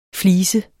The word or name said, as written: FLISER